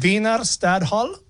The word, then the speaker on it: Wiener Stadthalle: It's at the Wiener Stadthalle.